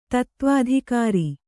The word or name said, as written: ತತ್ತ್ವಾಧಿಕಾರಿ